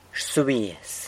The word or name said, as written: rsubiaz